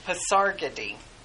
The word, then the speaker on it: Pasargadae: I actually listened to the little pronunciation on Wikipedia or something. So, Pasargadae.